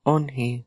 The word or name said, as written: on-hi